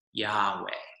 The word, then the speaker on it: Yahweh: Most biblical scholars agree that the most likely sound of the name is pronounced Yahweh.